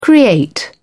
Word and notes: create: British English

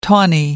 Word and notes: tawny: American English

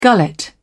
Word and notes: gullet: British English

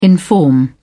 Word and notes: inform: British English

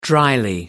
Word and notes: drily: American English

